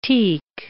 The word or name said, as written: teak